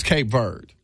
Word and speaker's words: Cape Verde: Because Reg told us it was Cape Verde. – I call it. – That the E is silent.